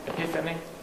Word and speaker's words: Epiphany: Mozilla isn't a GNOME application, but we do use it in indirectly in how is it pronounced? Epiphany. Okay, everyone heard it.